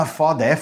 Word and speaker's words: foda: They'll be like, oh, FODA, FODA. FODA is that's the that would be the Brazilian pronunciation.